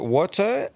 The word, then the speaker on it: Wouter: Now, apologies for pronunciation here, because it's a bit of a hard one, but is it Wotter? It's spelled W-O-U-T-E-R. – You want me to read it, mate? – W-O-U-T-E-R. How do you pronounce that?